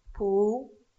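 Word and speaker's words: pu (不): Entonces, vemos cómo ese no que normalmente se pronuncia pu, cambia al segundo acento cuando después viene un verbo, o una palabra, con el cuarto acento. No nos vamos a encontrar un pu, chao, porque se cambia.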